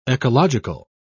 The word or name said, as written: ecological